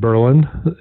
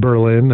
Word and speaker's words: Berlin: We pronounce it Berlin. Not Berlin, as folks who aren't from there might refer to it.